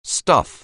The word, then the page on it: stuff: anglais